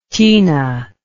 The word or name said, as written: tina